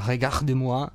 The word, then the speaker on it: Regarde-moi: La nuova canzone di tratta dal disco di Fra Quintale, la pronuncia l'abbiamo preparata con la R graffiata, Regarde-moi.